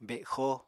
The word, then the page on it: bexo: Apatani